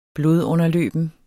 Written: blodunderløben

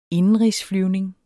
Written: indenrigsflyvning

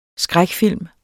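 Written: skrækfilm

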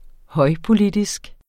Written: højpolitisk

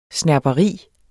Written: snerperi

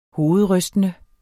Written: hovedrystende

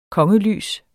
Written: kongelys

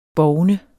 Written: BOVNER

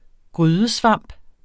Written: grydesvamp

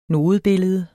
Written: nodebillede

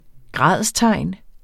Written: gradstegn

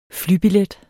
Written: flybillet

